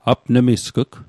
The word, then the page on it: apnmisg'g: apnmiskîk; Mi'gmaq